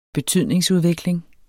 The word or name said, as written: betydningsudvikling